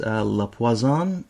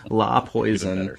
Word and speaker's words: La Poison: So next we have a familiar filmmaker to me, and I think you, Sacha Guitry's La Poison, or is that how you say it? – I think so, yeah. I think you got it. Good French there. – Or Le Poison. La Poison.